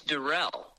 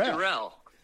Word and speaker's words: Deruelle: It's Deruelle. – Deruelle. – It's Deruelle. You were trying to make it a little French. It's just Deruelle.